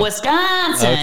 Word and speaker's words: Wisconsin: If you're from Wisconsin, there you say go. Wisconsin. – Okay. I just needed to know. – I'm trying to work on my accent, of course, right? So Wisconsin.